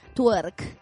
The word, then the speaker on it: twerk: ¿Cómo, cómo lo pronuncio? Tuer, eh, tuer, tuer twerk.